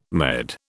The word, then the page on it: Med: British English